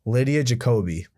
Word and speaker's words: Lydia Jacoby: How do you pronounce her name? – Lydia Jacoby.